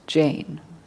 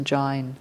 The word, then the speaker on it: Jain: So he's debating with a Jain, sometimes pronounced Jain, which is another religious school, basically.